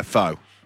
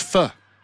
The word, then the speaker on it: pho: No, pho. – Oh, I thought it was pho.